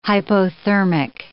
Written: hypothermic

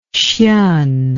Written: hsien

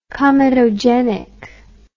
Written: comedogenic

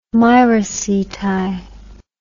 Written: miraceti

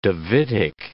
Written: Davidic

